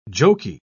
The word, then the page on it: joky: British English